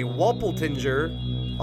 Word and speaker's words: Walpertinger: It's called the Walpertinger. Wait, what? Walpertinger. – Walpertinger. – That's exactly how to say it. In German folklore, a Walpertinger, also called Walpeldinger or Walpoldinger.